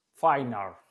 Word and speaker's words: Feyenoord: Feyenoord.